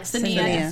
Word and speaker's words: Saniya: Saniya. – Sorry, didn't do that. – It's spelled Saniya, but it's pronounced Saniya.